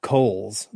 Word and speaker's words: Cowles: Yeah, I thought it was Cowles, and then then I think his I think his Twitter handle says Coles, like and he like he like phonetically oh, okay. spells it out.